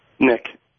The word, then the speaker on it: Nick: Nick.